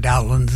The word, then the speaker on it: Dowland: But most consensus now says that it's a short O, because there's a composer by the name of Cowper who's contemporary, and so Cowper, Dowland, something like that.